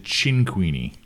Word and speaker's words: Cinquini: So it's an Italian last name. And okay. The correct way to say it is Cinquini.